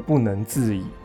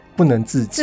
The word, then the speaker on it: 不能自已: 对，例如说我很受不了那个，我感动到不能自已。很多人都念成我不能自己，自己这个我不能接受，我完全不能接受。